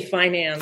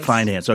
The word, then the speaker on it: finance: Which syllable do I accent properly? Is it finance or finance? – I say finance. – Finance.